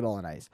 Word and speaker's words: bolognese: You don't pronounce the G. – But you can't, it's kind of there, isn't it? Bolognese. Is that better? – Yeah.